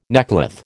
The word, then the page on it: neckcloth: American English